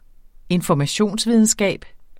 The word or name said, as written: informationsvidenskab